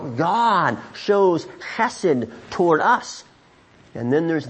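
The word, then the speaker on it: chesed (חסד): Now, the Old Testament word for this kind of love is a word pronounced chesed.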